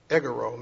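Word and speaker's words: egoro (ἐγείρω): So the word rise in the Greek is pronounced egoro, meaning to rise from sitting or laying and to rise from death.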